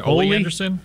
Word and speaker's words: Ole Anderson: Ole Anderson.